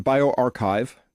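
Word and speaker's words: bioRxiv: Well, now this is from a preprint in BioArchive. That's how you pronounce this little piece up here, R. That's the Greek letter chi. So archive. Clever.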